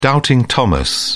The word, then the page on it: doubting thomas: British English